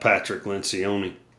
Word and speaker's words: Patrick Lencioni: Patrick Lencioni, and I may have just mispronounced his name, I'm not sure, but no, that was it.